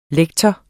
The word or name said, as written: lektor